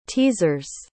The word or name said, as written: teasers